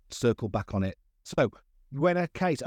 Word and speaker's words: Buena: Well, it's it's Buena, but it's pretty good what you said. And it's like there's a chocolate bar called Bueno, which you might know, and Buena's the feminine of that.